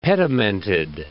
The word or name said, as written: pedimented